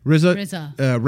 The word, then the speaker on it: RZA: RZA.